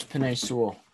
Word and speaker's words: Penei Sewell: Come on, it's Penei Sewell.